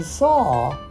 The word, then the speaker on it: saw: But in saw, the W does not have a clear sound. It just makes the A sound go longer. Saw.